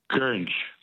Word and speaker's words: Goringe: The word that rhymes with orange is gurnge. – Is what? Gurnge? – Gurnge, yeah. – What's the spelling of gurnge? – G-O-R-I-N-G-E.